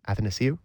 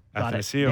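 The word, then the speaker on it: Athanasiou: The one that Muzzin had on on on uh, Athens. Oh, I can't ever say his name. Athanasiu. – Athanasiu nailed it.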